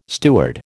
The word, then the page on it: steward: American English